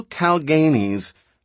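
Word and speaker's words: Kalganese: "Now, why do these terrible Calganis," she accented the second syllable and gave it a long A, "do like that?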